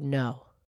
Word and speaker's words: know: And you don't pronounce the K in the word no. K-N-O-W.